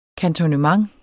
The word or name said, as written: kantonnement